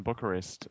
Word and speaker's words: Bucharest: I remember seeing it in live in Bucharest. Is that how you pronounce it? – Yes. Well, you would pronounce it like that. Eastern Europeans pronounce it differently, but yes.